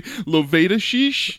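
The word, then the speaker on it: Liulevicius: So I would have to say Lovedashish? – Exactly. As we were listening to prepare how to pronounce this last name.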